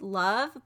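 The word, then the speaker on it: Lauv: My other one is I Like Me Better by, I think the artist is pronounced Love, but it's L-A-U-V.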